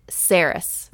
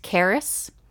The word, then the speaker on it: Cerys: It's spelled C-E-R-Y-S, but I have pronounced it Saris from the very beginning. So we're sticking with that. I'm sorry to the author if that's the wrong way to pronounce it, but it doesn't have to be the same way you pronounce it. You know, you could pronounce it Caris and I could pronounce it Saris, but my baby's name will be Saris Amber Lee Shear.